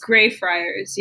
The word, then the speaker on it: Greyfriars: Is that how you pronounce it? Greyfriars? – Greyfriars, yeah.